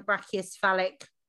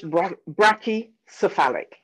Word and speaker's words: brachycephalic: Okay, so you've got an extra O in that. So it's just brachycephalic.